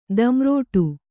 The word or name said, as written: ದಂರೋಟು